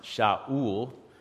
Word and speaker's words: Saul: In Hebrew, the name is actually pronounced Shaul, which means desired or prayed for or asked for.